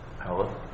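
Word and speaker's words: Halav (חלב): Halav.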